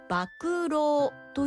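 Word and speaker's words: 博労: 語源はバクロウという言葉です。漢字で書くと博物館の博にいたわる、苦労の労ですねと書いて馬苦労、もしくは馬が食う、食うは口辺に食事の食ですね。これで馬苦労と読みます。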